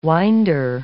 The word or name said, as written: winder